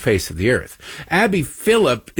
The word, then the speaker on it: Phillip: And you cite April Ryan and Abby Phillips. It's Abby Phillip, by the way.